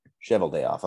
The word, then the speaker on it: Cheveldayoff: Kevin Cheveldayoff, I cannot pronounce his name, I think it's Cheveldayoff, What's that? – Cheval day off.